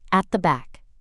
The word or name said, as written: at the back